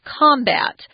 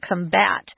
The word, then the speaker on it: combat: If it's a noun, it's combat. So the emphasis is on the first syllable. If it's a verb, it's on the second, combat.